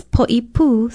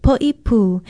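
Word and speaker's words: Poipu (Poʻipū): It is often mispronounced because people see the first three letters as a group and pronounce it as they would poi. It's Poipu, spelled P-O-Okina-I-P-U, and there's a stress on the last vowel.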